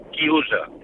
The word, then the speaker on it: chiusa: Y un momento, y quiero decirle a Fernando italiano se dice chiusa. Coro a boca chiusa.